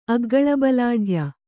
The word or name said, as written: ಅಗ್ಗಳಬಲಾಢ್ಯ